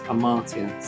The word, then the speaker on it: Amartya: I said Amariatan. – Right. It's Amartya.